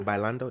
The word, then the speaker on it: bailando: Bailando? Is that how you say it? Dancing? – Yeah, yeah. Bailando.